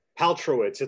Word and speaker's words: Paltrowitz: Paltrowitz. It's like Gwyneth Paltrow with the its at the end. – What is it? What is it? – Paltrowitz.